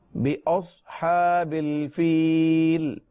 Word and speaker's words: بأصحاب: ربک, الم تر کیف فعل ربک, آپ بھی دہرائیے, الم تر کیف فعل ربک بأصحاب الفیل, بأصحاب الفیل, صاد ہے یہ, سین نہیں ہے, بأص اور حروف مستعلیہ ہر حال میں پر پڑے جاتے ہیں. بأصحاب الفیل.